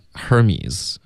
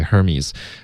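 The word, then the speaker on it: Hermès: 如果这是因为英文有这个字，它是发 Hermes，是一个对，希腊的一个神，对、哦、对，Hermes，可是应该不会有这么发这个品牌的，因为这个品牌有他自己法语的发音，对、啊、都发音，他就不读，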